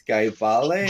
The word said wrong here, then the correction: Gyebale: Gyebale. Mm-hmm. – Gyebale. Okay, sorry.